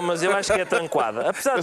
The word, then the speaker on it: Tranquada: o senhor Tranquada Gomes. Eu fui pesquisar. – Eh, tranquada ou tranca? Como é que se pronuncia? Tranquada, é tranquada? diz Tranquada Gomes.